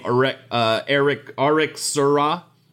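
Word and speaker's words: Eric Serra: He is credited on some films, including The Fifth Element, as RXRA, which is the French pronunciation of his name, Eric Seurat.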